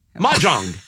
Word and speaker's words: Mahjong: Now you're saying John. – No, Jong. Mahjong. M-A-H. Ma. – Right. – J-O-N-G. Jong.